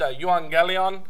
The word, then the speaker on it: euangelion: It's in Greek, it's pronounced euangelion, but often when you put that into English, that it's EU, but it changes to a V often in English for some reason, just because of the way the language developed in English.